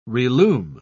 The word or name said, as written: relume